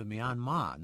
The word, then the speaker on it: Myanmar: And by the way, my understanding of the pronunciation of the name Myanmar is that it is closest to Myanmar, no accents. The R at the end of the English transliteration, an artifact of a British accent that uses a final R simply to extend the final vowel sound.